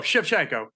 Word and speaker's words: Shevchenko: What's your take on the fight and my pronunciation? – Close. Shevchenko. Valentina Shevchenko is Shevchenko.